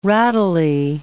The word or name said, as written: rattly